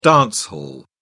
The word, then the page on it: dance hall: British English